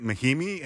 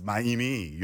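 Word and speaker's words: Mahinmi: So it wasn't Yan Maimi, it was Yan Maimi. And I started saying it like that on, and I'm like, I've seen him play, isn't it Maimi? And he's like, no, it's Maimi. You don't pronounce the H in French.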